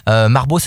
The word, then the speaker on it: Marboz: Marbeau, ça s'écrit M-A-R-B-O-Z. On ne dit pas le Z. – Interdit de dire le Z.